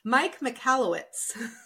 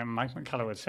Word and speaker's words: Mike Michalowicz: So the author, which is Mike Michalowicz. – Well done. Okay. Mike Michalowicz.